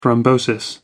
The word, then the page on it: thrombosis: American English